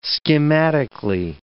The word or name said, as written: schematically